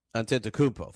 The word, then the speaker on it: Antetokounmpo: Giannis Antetokounmpo, right? Is that right? Okay. He said... Antetokounmpo. Thank you.